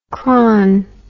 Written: chron

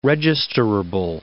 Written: registerable

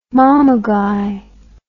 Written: mamaguy

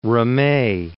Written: Ramee

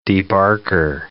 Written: debarker

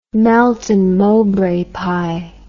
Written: Melton Mowbray pie

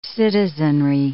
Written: citizenry

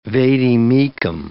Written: vade mecum

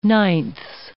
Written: ninths

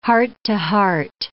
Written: heart-to-heart

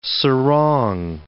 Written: sarong

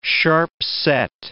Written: sharp-set

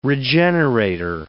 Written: regenerator

